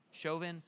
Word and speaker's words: Chauvin: What, Chauvin, am I saying that wrong? Chauvin, okay.